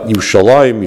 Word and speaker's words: Yerushalayim: People whose natural language is Aramaic would pronounce it Yerushalayim, and people whose natural language is Hebrew would pronounce Yerushalayim with a chirik, with a dot between the lamed and the mem that tells you that it's Yerushalayim, and that's not two different names.